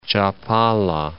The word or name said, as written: Chapala, Lake